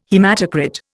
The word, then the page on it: haematocrit: Indian